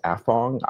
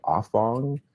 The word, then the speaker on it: Afong: My publisher says Afong. I say Afong because I think that's how my relatives would pronounce it.